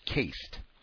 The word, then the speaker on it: cased: Two, I wanted to bring your attention to the pronunciation of cased. It is pronounced the same as cast, like you would have on a broken arm.